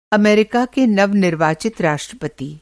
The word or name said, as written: नवनिर्वाचित